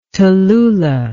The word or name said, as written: tallulah